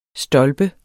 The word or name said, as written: stolpe